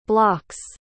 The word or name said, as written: bloc's